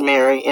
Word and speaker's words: Mari: I think it's pronounced Mary, M-A-R-I, they're both tennis players, and they looked up to Serena and Venus, you know.